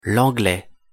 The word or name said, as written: l'anglais